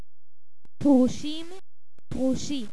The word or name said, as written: Perushim